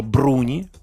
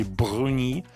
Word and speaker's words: Бруни: Э, зовут ее Карла Бруни, по-итальянски ударение, по-французски Бруни. И она экс первая леди французской. – Mm-hmm. Карла Бруни Саркози.